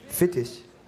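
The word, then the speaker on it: Fetisch: How do you say fetish in Deutsch? Fittisch. Fittisch und Strapse.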